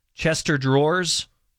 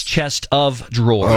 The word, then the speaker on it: chest of drawers: But you're saying it wrong. It's chest of drawers.